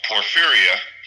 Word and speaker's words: porphyria: She was diagnosed with a rare disease called porphyria. It's pronounced like the fear of being poor, but it has to do with how your body manufactures red blood cells.